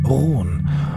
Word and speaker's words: Rone: die haben das trotzdem französisch ausgesprochen, eben Rouen.